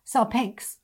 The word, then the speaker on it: salpinx: It is salpigix. In fact, I need to look up the pronunciation of that. Salpinx.